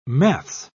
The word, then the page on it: maths: British English